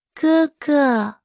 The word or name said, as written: 哥哥